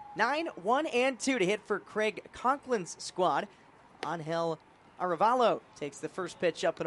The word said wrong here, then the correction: Arevalo: Arevalo, I beg your pardon. Still trying to get that pronunciation right. Emphasis on the Ray. Angel Arevalo.